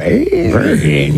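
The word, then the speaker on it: Bane: I think that Bain. – Yeah. – You have to say his name like that.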